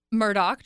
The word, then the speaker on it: Murdaugh: Murdaugh trial.